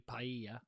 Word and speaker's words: paella: I think it's probably paella. – Paella. – Because the double L makes a Y sound.